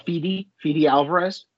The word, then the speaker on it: Fede Alvarez: Fede Alvarez.